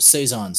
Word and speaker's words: Saison: but is a more popular one is a Saison, if I'm saying that correctly. – Yep, Saison.